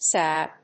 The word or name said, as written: sab